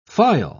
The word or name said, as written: phial